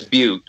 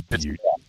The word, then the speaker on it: Butte: It's not supposed to be pronounced that way, but, you know, no, I believe it's Butte. I believe that's how it's. – Yes, it is Butte. – But we all call it Butt.